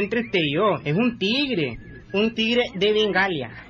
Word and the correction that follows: Bengala: En primer lugar, no se dice Bengalia. – ¿Y cómo se dice? – Se dice Bengala.